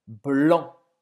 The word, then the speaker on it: blanc: Branco em francês é blanc. Blanc. Você não vai pronunciar esse C no final.